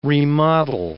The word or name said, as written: remodel